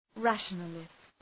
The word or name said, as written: rationalist